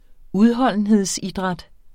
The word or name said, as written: udholdenhedsidræt